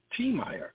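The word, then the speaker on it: Tiemeyer: And in case you don't know how to spell my name, it's T-I-E-M-E-Y-E-R, T. Meyer. The last name is McCain, M-C-C-A-I-N. And Tasila, her name is spelled T-O-S-I-E-L-A, Tasila.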